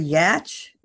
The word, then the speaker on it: Yatch: This is part two of my awesome, awesome discussion with retired Navy SEAL, Larry, and I hope I'm pronouncing this, Larry Yatch.